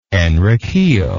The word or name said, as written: enriquillo